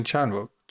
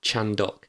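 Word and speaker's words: Chandhok: K-A-R-U-N. Karun. – Karun Chanwook. – Chandok.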